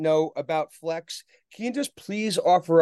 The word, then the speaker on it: Flexe: The most important thing about Flexe is that the second E is silent, so just Flexe.